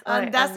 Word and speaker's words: Ondas: Puis finalement, tu as décidé de justement te diriger complètement vers ça pour lancer Ondas, Mar Ondas ou Ondas, je sais pas comment le prononcer. Ondas, Marketing.